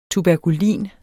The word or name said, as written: tuberkulin